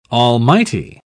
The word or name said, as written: almighty